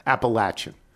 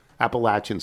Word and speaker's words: Appalachian: The correct pronunciation of the university is a short A in the middle. Appalachian. Appalachian State.